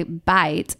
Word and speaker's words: bite: For instance, in France, you say bite, pronounced like beat, which means penis.